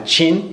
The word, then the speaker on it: Qin: In English, it's spelled Q-I-N, but it's pronounced Qin.